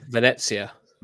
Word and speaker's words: Venezia: Uh, Venezia.